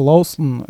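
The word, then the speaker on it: Lahusen: Лахусен пишется, и это Лаусен,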